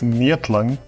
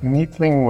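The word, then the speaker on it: Neethling: I mean, if you want the like proper Afrikaans pronunciation, then it's Neathling. But Neathling works in a pinch.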